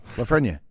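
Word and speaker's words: Lafrenière: So Alex Lafreniere, is that how you say it? – Yeah, Lafreniere.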